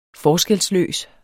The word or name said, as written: forskelsløs